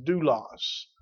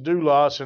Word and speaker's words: doulos: But that word servant is a Greek word pronounced doulos. It's pronounced doulos, and it literally means slave.